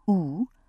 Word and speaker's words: Aodh: I was used to the peculiar names of Celtic saints, from A-O-D-H, pronounced Oo, to Dervogilla, but hadn't heard of Saint Adso before.